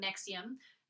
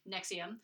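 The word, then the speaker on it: NXIVM: Like, if you listen to these people, especially so if you learn anything about Nexium, NVIXM, it's spelled really dumb, Nexium.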